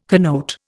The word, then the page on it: connote: Indian